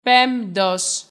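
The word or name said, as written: πέμπτος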